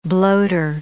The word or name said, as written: bloater